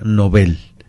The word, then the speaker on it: Nobel: Lo cierto es que es con N mayúscula, con B, y que se pronuncia Nobel. ¿Por qué? Porque se respeta la pronunciación que viene del sueco de origen, y de hecho, si lo pensamos, Nobel es una palabra aguda que termina en una consonante y por lo tanto no debe ir acentuada, como correctamente lo es.